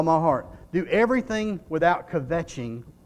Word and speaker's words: kvetching: Do everything, okay, the word is spelled K-V-E-T-C-H-I-N-G. Kvetching is what I would say. The K could be silent. It might be vetching, okay?